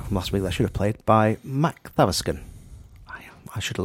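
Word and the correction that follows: Makthaverskan: mock. It's Mock the Furskin. – Emphasis on. – Yes, emphasis on the first and third syllables. Yes. And the M-A-K is an M-O-C-K sound to us.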